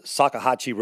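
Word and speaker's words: Salkehatchie: And I believe it's in the 1400 block of the Sakahatchee Road. Is that how we learned how to talk, say, pronounce it? – I think that's right.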